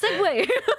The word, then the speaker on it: segue: You ever pronounce segue as segue? – Oh, dude, I only recently learned how to. I only recently learned that segue was spelled in that way. I've been spelling it S E Y W A Y. Sorry, S E G. Sorry, not S E Y. – Segue. – I've been pronouncing it. I've been spelling it S E G W A Y. Not segue. Segue. – Yeah. It's because it's French, dude.